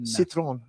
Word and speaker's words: Citroën: How do you say that? – Citroën. – Citroën. – That's yeah, not Citroën. – Not Citroën. Not Citroën. – Citroën, yeah.